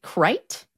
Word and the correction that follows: Crich: Not far off. It's Critch. – Critch, okay. – Yeah. If you pronounce it any other way, you ain't from around here.